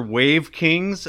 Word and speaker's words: Wavkingz: The two songs are under the moniker Wave Kings, and that's spelled W-A-V-K-I-N-G-Z.